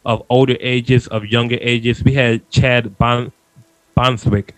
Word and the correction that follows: Chadwick Boseman: from, I hope I said his name right, from Black Panther. – Chadwick Boseman, thank you.